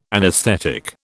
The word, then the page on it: anaesthetic: British English